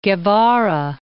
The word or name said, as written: Guevara